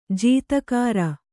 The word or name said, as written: ಜೀತಕಾರ